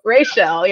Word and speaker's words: Rachelle: So fun story, my dad is from Jamaica, and so they put the emphasis on both syllables there. So Rachelle, yeah.